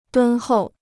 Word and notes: 敦厚: dūn hòu

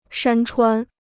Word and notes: 山川: shān chuān

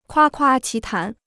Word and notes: 夸夸其谈: kuā kuā qí tán

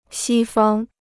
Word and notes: 西方: xī fāng

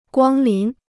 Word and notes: 光临: guāng lín

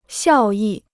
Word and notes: 效益: xiào yì

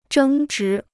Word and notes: 争执: zhēng zhí

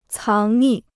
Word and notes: 藏匿: cáng nì

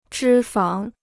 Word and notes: 脂肪: zhī fáng

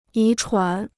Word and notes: 遗传: yí chuán

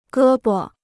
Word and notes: 胳膊: gē bo

